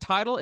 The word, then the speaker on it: Tidal: Tidal, is it Tidal? Is that how you pronounce it? – Yeah, like Tidal Pool, T-I-D-A-L.